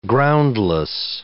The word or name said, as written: groundless